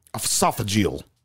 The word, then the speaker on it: esophageal: Esophageal.